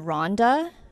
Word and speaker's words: Ronda: She grew up in Ronda.